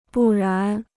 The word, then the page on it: 不然: bù rán